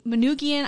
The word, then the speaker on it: Manoogian: Manoogian, I am pronouncing that right because I checked in with one of my Michigan friends, my buddy, and he told me... Yeah, so I know I'm saying that right.